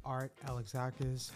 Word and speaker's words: Art Alexakis: You are listening to On Air with JT, and I have a very special interview with frontman of Everclear, Art Alexakis.